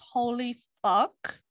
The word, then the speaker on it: Holy F4K: I'm pretty sure it's pronounced holy fuck, but it's written like holy F- fork.